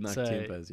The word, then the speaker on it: tiempos: Is that how you pronounce it? Tiempos? – No, it is. It's called tiempos, yeah.